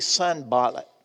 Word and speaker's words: Sanballat: It begins in verse one when Sanballat, now I've been calling him San, Sanballat I put the accent on the last syllable, but I think it's technically Sanballat. I, I like Sanballat. Let's change it.